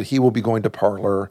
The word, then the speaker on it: Parler: Called Parler, P-A-R-L-E-R. – Okay. – The Q is silent.